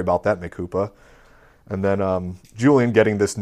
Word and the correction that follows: mea culpa: Instead of mea culpa, Mikupa. I really like it. Okay, Mikupa. I'm sorry. Mikupa. – That's how it's pronounced, right? – No, it's mea culpa. But I will accept Mikupa.